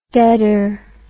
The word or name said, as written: deader